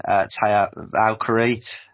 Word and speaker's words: Taya Valkyrie: Deonna Purrazzo defending against the returning Taya Valkyrie. Apologies if I pronounced that wrong. – No, that sounded right to me.